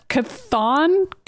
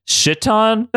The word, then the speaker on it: Chthon: And during that time, Wanda gets possessed by, I don't know how to pronounce this, C-H-T-H-O-N. That's a demon that exists in the Marvel universe. – Kathan? Kathan? – I've always pronounced it Shitan. Like, Shitan? – Great, it's Shitan.